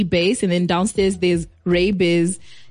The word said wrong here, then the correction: Ray Diz: J. – Ray Diz? – Yeah. Ray Diz, J.